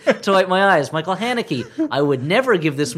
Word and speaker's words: Haneke: Haneke. – That's close enough. – No, it is not at all. One sounds like Hanky, and one sounds like Hanukkah.